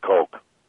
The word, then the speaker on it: Koch: Now, the Koch brothers also... – I think they pronounce it Coke.